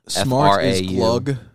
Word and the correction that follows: klug: Mm-hmm. – Clug. – Klug. It's Klug. – Klug, yeah. – It's spelled Klug.